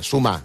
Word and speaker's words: Sumar: El govern espanyol pressiona Podem perquè s'afegeixi a sumar dos asteriscos i a peu de pàgina diu sumar es pronuncia en català. Sumar. – Sumar. – Sumar, no sumar.